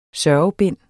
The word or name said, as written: sørgebind